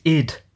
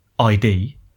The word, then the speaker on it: id: Often people say ID, and you go, id.